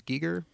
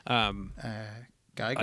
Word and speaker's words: Giger: And then to see the organic machines, it feels like you could draw a direct design lineage from H.R., is it Giger? I think it's Giger. – Geiger, I've heard it pronounced Geiger, but I'm not German, so I.